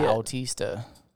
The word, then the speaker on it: Bautista: There's an A and a U. It's a Bautista. – That's not how you say it. – Bautista. I think it is. I used to say Bautista because that's how it was in WWE. – That's how you say it. – But I think it's Bautista. – Okay, it might be Bao, but you're saying Boo.